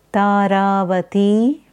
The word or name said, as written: Taravati